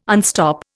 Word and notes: unstop: Indian

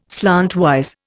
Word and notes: slantwise: Indian

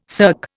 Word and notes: cirque: Indian

